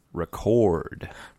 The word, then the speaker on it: record: Yeah, the same spelling, hmm. But hmm. it's pronounced record.